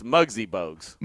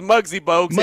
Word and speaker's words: Muggsy Bogues: First of all, it's Muggsy Bugs. – Muggsy, Mosey, who cares, man? – So you can't be the next Muggsy Bogues if you got his name wrong.